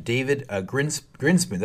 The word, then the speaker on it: Grinspoon: Yes, and we're here, luckily, with Mr. David Grinspoon. Did I pronounce that correctly, David? – Yes.